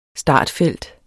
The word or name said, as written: startfelt